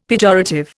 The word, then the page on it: pejorative: Indian